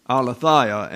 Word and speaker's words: aletheia: Apo, aletheia, actually, is the accents on the syllable at the end.